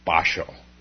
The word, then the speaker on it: Basho: Now, the Japanese pronounce Bachao, Basho. But don't confuse this with the famous Japanese haiku poet, Basho.